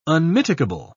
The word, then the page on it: unmitigable: British English